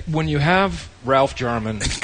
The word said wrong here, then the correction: Garman: Garman. – I'm sorry. I keep doing that. – Hard G.